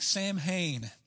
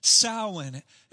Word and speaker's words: Samhain: And if you look there on the timeline, to a Texan, that looks like Samhain. And I guarantee you, I'm going to say Samhain at some point tonight. So just understand, it's pronounced Samhain.